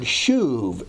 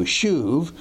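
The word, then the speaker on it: shuv (שוב): That's the Hebrew word shuv, S-U-V, long U, shuv, or S-U-B, but usually pronounced as a V.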